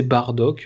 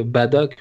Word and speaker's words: Bardock: Euh, Bon voilà, donc c'est Bardock, euh, c'est officialisé. Badak, on peut le dire, euh, c'est pas entièrement faux, c'est la prononciation française de la prononciation japonaise.